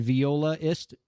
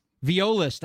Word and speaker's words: violist: Again, we are chatting with Sarah Elizabeth Haines, indie singer songwriter and violinist and violaist. How do you say that? – Violist. – I like that. Makes more sense.